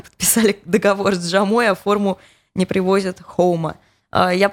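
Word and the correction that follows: Joma: просто почитала, это испанская фирма, поэтому произносится как, читается как Хома или Хома.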